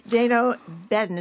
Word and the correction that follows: Dana Bedden: Is that how you say it? – Dana Bedden.